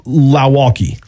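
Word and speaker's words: Lauaki: Last name is spelled L-A-U-A-K-I. L-A-U-A-K-I. I've got Lowaukee.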